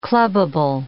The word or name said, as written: clubable